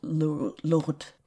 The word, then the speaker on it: Lourdes: And there was a sanctuary created for Our Lady of Lourdes. I don't know how to pronounce that. – Yes, you're doing a good job.